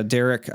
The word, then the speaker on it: Darick: So it's uh, Darick Oswalt, but Darick is spelled weird. This is part of the reason I'm forgettable. So Darick is spelled weird. Weird. It's D A R I C K.